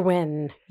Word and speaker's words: Nguyen: Dr. Nguyen, how do you say? – Dr. Nguyen, yes.